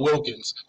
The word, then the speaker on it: Wilkins: Watkins, William, everything but Wilkins.